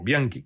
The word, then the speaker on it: Bianchi: Um detalhe, é, antes que eu citei o Carlos Bianchi, por que o Carlos Bianchi, ou Bianchi, que seria a pronúncia correta, é, é, era denominado antigamente como El virrey de Liniers, o vice-rei de Liniers.